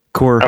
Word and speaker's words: Magny-Cours: It is in France, as they tout on the teaser, and looks like it's the longtime home of the French Grand Prix, Magny-Cours. Is how I'm pronouncing it, whether Coors. It's right or not. – Coors. – Okay. Well, I thought I didn't know they pronounce hard R's in French that much.